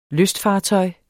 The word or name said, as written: lystfartøj